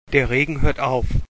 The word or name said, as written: aufhoeren